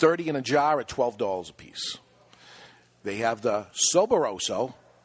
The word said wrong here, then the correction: Soberbios: Am I saying that right? Soberbios? Soberbios. That's what I said.